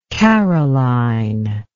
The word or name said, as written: karoline